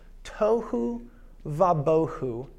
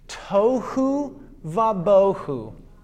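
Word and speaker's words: tohu vabohu (תהו ובהו): And it's described as being formless and empty, which in Hebrew is pronounced tohu vabohu. And yes, I'm going to make you learn this phrase with me. Everyone with me. Tohu vabohu. Again, tohu vabohu.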